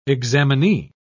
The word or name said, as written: examinee